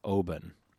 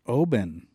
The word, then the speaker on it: Oban: And I should probably pronounce it the way that they do, which is Oban. – Oban. – Mm-hmm. Oban. – Okay. – Like O and bun, like a bread bun.